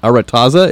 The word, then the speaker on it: Aretaza: Now, this is the first novel that you've written set within what you call the world of, and I hope I say this right, Aretaza. Is that right? – That's correct. That's exactly correct.